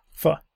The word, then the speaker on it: pho: The faux cookbook. – Pho.